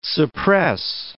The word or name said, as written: suppress